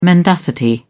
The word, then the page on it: mendacity: British English